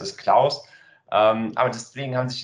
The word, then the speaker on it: Clous: Äh, wir werden Klaus ausgesprochen das ist der Ingenieur Klaus und die Cloud.